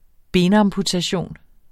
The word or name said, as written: benamputation